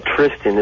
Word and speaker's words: Tristan: I like Tristan. I do. Yeah, it rhymes with Fiston.